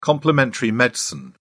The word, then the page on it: complementary medicine: British English